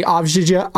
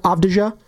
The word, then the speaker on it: Avdija: They got rid of, who's my guy, Denny Avdija? – Avdija.